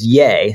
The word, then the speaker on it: Ye: I referred to him as Ye, which apparently it's Ye. So I didn't know that's how it's pronounced, but Ye.